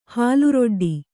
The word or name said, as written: ಹಾಲರೊಡ್ಡಿ